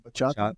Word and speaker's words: bachata: I would always show him videos of baracha. Did I say that right? – Bachata. – Oh, bachata. Bachata.